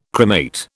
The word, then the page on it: cremate: British English